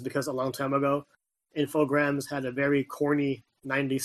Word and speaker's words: Infogrames: First of all, it's pronounced Infogrames, but nobody cares because whatever.